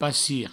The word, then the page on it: passir: passi